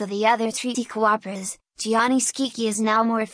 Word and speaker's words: Gianni Schicchi: Gianni Schicchi, Italian pronunciation, Danny Schicchi, is a comic opera in one act by Giacomo Puccini to an Italian libretto by Giovacchino Forzano, composed in 1917-18.